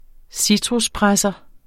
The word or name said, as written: citruspresser